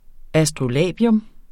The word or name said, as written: astrolabium